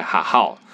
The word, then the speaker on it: Hahow: 那我们会在哈号这个平台 H A H O W，它其实是一个台语学校的发音，哈号，它把它翻成。